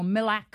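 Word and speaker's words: MLAK: We were just talking before about these keys that I must admit I'd never heard of, MLAX. Did I say that correctly? – Well, it's the Master Locksmith Access Key, MLAC or MILAC for short.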